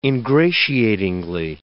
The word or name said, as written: ingratiatingly